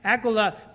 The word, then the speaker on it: Aquila: But it's a hard k sound, at least in Greek, for both Prisca and Aquila.